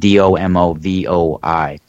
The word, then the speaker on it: Domovoi: It's called the Domovoi, I think they pronounce it, Domovoi.